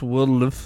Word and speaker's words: WLDLFE: It's actually pronounced Wildlife.